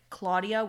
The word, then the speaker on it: Claudia: So, and me personally, like my name is Claudia. Ever since I was little, I've always associated name like Claudia with cloud, because like in Portuguese, my name is pronounced Claudia. Mm-hmm. So it's like cloud.